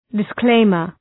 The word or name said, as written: disclaimer